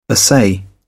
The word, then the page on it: assay: British English